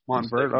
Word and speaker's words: Montverde: Is that how you say that? Montverde? – I think Montverde, if I'm not Montverde. Oh, the E is silent.